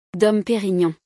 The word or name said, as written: Dom Perignon